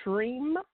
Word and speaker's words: Shreem: The second thing is that if you are working on, you know, bringing more income into your life, the mantra to chant, is Shreem Brzee. That's Shreem Brzee. It's spelled S-H-R-E-E-M. And the next word is Brzee, B-R-Z-E-E.